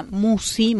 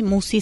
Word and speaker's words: Musci: El canal lleva mi nombre, Marisa Musi. Musi se escribe M-U-S-C-I.